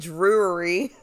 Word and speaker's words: Drury: Are you saying jewelry? – That's what it sounds like. I'm saying Drury.